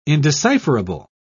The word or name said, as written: indecipherable